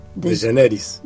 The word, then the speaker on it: DeGeneres: DeGeneres.